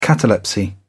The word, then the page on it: catalepsy: British English